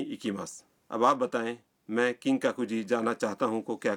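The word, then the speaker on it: کنگ ککوجی (金閣寺): مثلا آپ کیوتو کے کنگ ککوجی مندر جانا چاہتے ہیں. مندر کے نام کا تلفظ ہے کنگ ککوجی.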